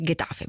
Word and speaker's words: Getafe: Ho detto bene, Getafe, benissimo.